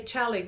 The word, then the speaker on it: Xali: So um, Mexicans usually say chali gold.